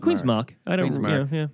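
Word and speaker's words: Queen's Marque: Did you pronounce it Mark? – Mark. Oh, yeah. Well, actually, I had this discussion on the weekend with my wife because some people called it Queen's Marquee.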